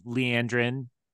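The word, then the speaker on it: Liandrin: You know, I hated Leandrin. I pronounced that right. Yep.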